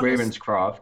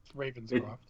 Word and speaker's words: Ravenscroft: He's best known Ravenscroft. – As... – Ravenscroft. – It's really that. It's spelled exactly like it.